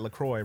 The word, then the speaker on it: LaCroix: They say on their website, LaCroix rhymes with enjoy.